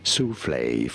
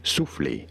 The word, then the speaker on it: soufflé: Soufflé, for reference in French, it is said as souffle. Souffle. – Souffle. – In English, however, souffle. Souffle is how souffle.